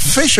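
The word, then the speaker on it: ghoti: Giotti or Gotti is pronounced exactly like the word fish, apparently. The G-H, as in tough, making the F sound.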